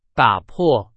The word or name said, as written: 打破